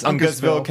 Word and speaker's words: Uncasville: Then the following weekend, August 9th through 11th, is Terrificon in Uncasville, don't know how to say it. – Uncasville, Connecticut, featuring John Barrowman's Fabulous Cabaret, plus guests Billy D.